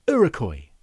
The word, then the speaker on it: Iroquois: Just like Rudy Rosenberg did, who has corrected a few facts for me, uh, told me that Iroquois, which I struggled to pronounce back in episode number 34, is pronounced Iroquois.